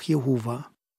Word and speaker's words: Jehova: Und so kommt man dann zu der Aussprache Jahwe oder Jahwe oder auch Jehova.